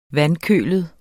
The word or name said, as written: vandkølet